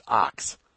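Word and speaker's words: Ochs: Louis Marshall contacted Adolf Ox, pronounced Ox. Glad that's cleared up.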